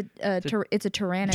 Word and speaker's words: Pteranodon: And he's like looking at a pterodon. I think that's how you say it. – Yep. – So bad at pronouncing dinosaur names. – Yeah, it's a pteranodon.